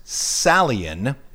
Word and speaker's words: psallein: Don't want to go against that, but if you're dealing just with the word, psalm is an old Greek verb which is pronounced salien, I assume, P-S-A-L-L-E-I-N, and what it means is to pluck a stringed instrument, generally a harp.